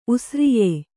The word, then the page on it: ಉಸ್ರಿಯೆ: usriye; Kannada